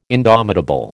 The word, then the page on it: indomitable: American English